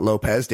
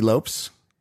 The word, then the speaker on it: Lopes: The then former Dodgers second baseman, Davy Lopes, not Lopez, Davy Lopes.